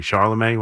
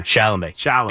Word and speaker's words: Chalamet: Charlemagne? Why do I always say his name? – Chalamet.